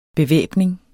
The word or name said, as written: bevæbning